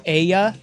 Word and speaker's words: ella: Ella is pronounced Aya. It means she in Spanish.